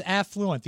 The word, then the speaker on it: affluent: It's affluent. The accent is on the A.